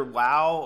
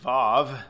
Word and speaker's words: vav: This one begins with the next Hebrew letter, wow, or the modern Hebrew would pronounce it vav, so a W or a V sound, and we translate it as and.